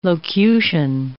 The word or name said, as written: locution